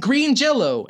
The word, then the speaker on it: Green Jelly: It's spelled Green Jelly, but pronounced Green Jello.